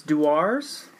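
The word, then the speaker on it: Dewar's: It's, this is controversial pronunciation, but it's Duars.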